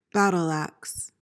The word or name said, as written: battle-axe